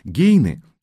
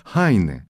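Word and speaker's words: Heine: Мы называем Гейне – Гейне, а ведь точное произношение этой фамилии будет Хайне.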